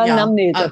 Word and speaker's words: Anamnese: Und du hast quasi erstmal so ein Anamnesegespräch. Habe ich es richtig gesagt? – Ja, Anamnese.